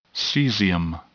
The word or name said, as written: caesium